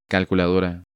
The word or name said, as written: Calculadora